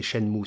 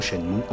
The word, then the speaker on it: Shenmue: espèce imaginée par Yu prononcer « Shenmue sûrement pour se rapprocher de la sonorité de Jianmu, alors que le kanji choisi par Yu Suzuki, différent du Hanze chinois de Jianmu, devrait se prononcer Sha-mu,